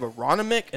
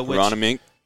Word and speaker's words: Aronimink: You have Aronimink. And then you have PGA Frisco. – Aronimink. Yep. – Aronimink.